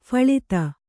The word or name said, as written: ಫಳಿತ